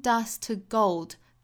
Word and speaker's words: dust to: Turning dust to gold. Dust 后面的 t 的发音把它省略掉，因为后面 to 呢也是 t 打头嘛，所以 dust to gold, turning dust to gold。